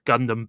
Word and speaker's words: Gundam: You're making a slight pronunciation issue there. It's Gundam Build Metaverse.